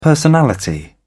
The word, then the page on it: personality: British English